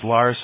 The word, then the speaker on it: Lars: My first name is Lars, so I've been called everything that rhymes with Lars. Lars, Mars, candy bars, the whole deal.